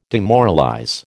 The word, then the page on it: demoralize: American English